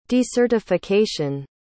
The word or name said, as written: decertification